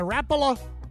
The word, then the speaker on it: Rapala: Big and Wild Fast Cast today brought to you by Rapala. That's the proper way to say it, not Rapala. Trust me, I get corrected by guys.